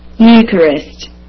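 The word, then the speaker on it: Eucharist: نسمع كده يا جماعة كلمة يوخارست كيف تنطق؟ انا بحاول اسمع عشان العوام بس. ها ماشي. – يوكارست يوكارست يوكارست يوكارست يوكارست. تمام. يبقى يوكارست يا جماعة الخير بتنطق ايه؟ الاي يو اتنطقت ايبسلون يبسلون يو.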